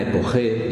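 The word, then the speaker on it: epoché: no por una epoge, diríamos en terminología de Husserl, ¿sabéis? Epoge, que se escribe E-P-O-C-H-E, suspensión del juicio.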